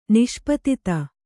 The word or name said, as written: ನಿಷ್ಪತಿತ